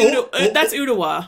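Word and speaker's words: Ooltewah: I'm going to spell it. O-O-L-T-E-W-A. – Oh, that's Ottawa. – Ottawa, yeah. – Yeah. That's no, Ottawa.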